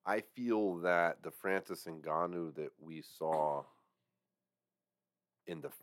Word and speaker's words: Ngannou: Hold on, you said his name wrong. You mean number 10 heavyweight in the world by the WBC, Francis Ngannou.